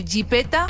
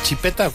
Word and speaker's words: jipeta: Ya no se dice coche, se dice jipeta, buggy o carrazo. – ¿Cómo has dicho primero? ¿Chipeta? ¿Cuál has dicho? – Jipeta. – Jipeta. Se llama jipeta.